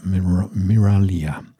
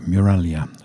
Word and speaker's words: Miraglia: First of all, as I said, Danielle Miralia. That's how it should be. Miralia is how it should be pronounced.